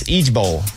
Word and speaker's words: IJBOL: Let's pronounce Idge Bowl. Idge Bowl. – It stands for something? – Mm-hmm. I-J-B-O-L.